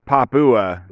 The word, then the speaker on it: Papua: I used to say Papua New Guinea, but it's Papua. So it's kind of like Papa Wa, but a little more Fiva to the flavor.